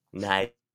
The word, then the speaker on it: Nighy: Bill Nighy? Nighy? Nye. – I always said Nye when I first saw it.